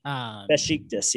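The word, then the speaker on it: Beşiktaş: When they got relegated, they sold him off to I think it's called Besiktas. Is that how you say it? – Besiktas, yeah, in Turkey.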